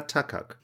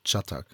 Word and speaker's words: Çatak: Ja, aber ich muss den Deutsch aussprechen, also Ilka Takak. – Nein, Çatak heißt das. – Katak steht hier, ganz nein, deutschlich. – Nein, das ist das C unten mit dem Dings dran, mit dem Kringel da.